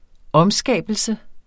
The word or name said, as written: omskabelse